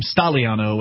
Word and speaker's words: Stagliano: So, by the way, I'm being informed that he apparently pronounced his name Stagliano without pronouncing the G.